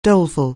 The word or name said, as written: doleful